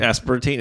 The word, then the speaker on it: aspartame: Aspartame. Aspartame. Is that how you say it? – Yeah. I think that's what's in G Fuel.